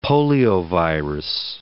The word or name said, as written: poliovirus